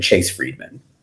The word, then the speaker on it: Chase Friedman: You could also see me on LinkedIn, Chase Friedman. – There you go. – D-M-A-N.